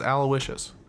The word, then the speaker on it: Aloysius: Yeah, it's Aloysius. – No, I knew a guy that had that last name, and he didn't pronounce it like that.